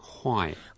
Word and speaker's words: 坏: Why? – Why is the fourth tone, and we need to say that as if they are so bad. Why? – Why? – Why? – Why? – Why means bad.